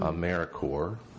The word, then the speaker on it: AmeriCorps: AmeriCorps. And that's spelled like corpse without the E.